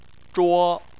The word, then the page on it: zhuo: 1